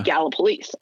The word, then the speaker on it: Gallipolis: Whereas for me, like if I mispronounce in Virginia or West Virginia, the town of Gallipolis, and I call it Gallipolis, somebody's going to come up to me afterwards and say, no, she moved to Gallipolis or Gallipolis, whatever it is.